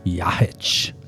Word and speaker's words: Jahic: Jahic.